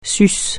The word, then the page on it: sus: French